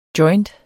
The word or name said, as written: joint